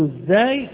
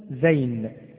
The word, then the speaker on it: الزاي: وفي الزاي ونقول الزاي ولا نقول زين، اسمها الزاي ولا نقول زين كما يقول البعض، ولكن اسمها الزاي، وهي مثل الراء، وتكون ونقول معجمة.